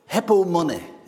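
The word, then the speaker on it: hypomone (ὑπομονή): The Greek word is hypome, or hypomone, is how I spelled it here, how you pronounce it.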